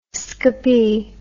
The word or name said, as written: scopy